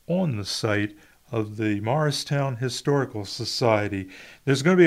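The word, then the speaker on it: Mauricetown: Come one, come all to Cumberland County Artist Day, and that is in the historic village of Morristown, New Jersey. You all know where Morristown is. You all know that it's spelled M-A-U-R-I-C-E, Morristown, south of Millville, north of Port Norris.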